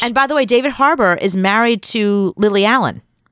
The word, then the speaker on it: Harbour: David, how do you say his last name? Harbour. – David Harbour.